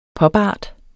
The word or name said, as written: popart